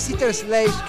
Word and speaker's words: Sister Sledge: Ah, pero creo que es Sister Sledge, que sería como el equipo de las hermanas, el conjunto de hermanas.